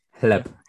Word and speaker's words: Hleb: Yes, Chleb. H-L-E-B. Chleb.